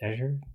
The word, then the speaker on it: Azure: ja ik, het Azure. Ja, het is de Engelse uitspraak.